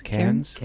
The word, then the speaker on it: Cairns: An aircraft flying from Sydney to, how do you pronounce that, Cairns? – Cairns.